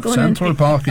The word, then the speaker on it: Central Park: Ja, Central Park i New York.